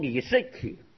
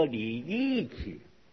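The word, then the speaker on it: 郦食其: 米粒的粒旁边有个九九米，像李，李世奇啊，就是个饮食的食，一个人读李世奇，哈、啊，没李世奇，噶读作李义奇，李义奇，这噶叫食食就读义，啊，个人名的食，啊，人名啊，李义奇，黑化读义，啊，没读食，也没读诗。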